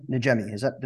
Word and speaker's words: Najemy: I'm here with Adam Najemy.